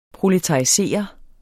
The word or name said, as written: proletarisere